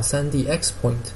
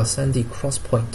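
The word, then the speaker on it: 3D XPoint: 那么 NAND 呢，速度慢，价格是便宜，所以呢，就英特尔和美光他们开发了叫 3D Crosspoint，就是叫 3D X Point，叫实际上是发音叫 3D Crosspoint。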